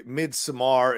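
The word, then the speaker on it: Midsommar: Yeah, and just before you get attacked in the YouTube, eh, mid Samar is how you, is how it's pronounced, but whatever.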